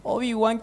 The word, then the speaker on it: Obi-Wan: Obi-Wan Kenobi.